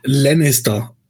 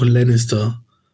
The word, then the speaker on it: Lannister: Na, wie sprichst du denn das A in Englisch aus? Da sagst du auch Lannister. Im deutschen Haus Lannister. Lannister.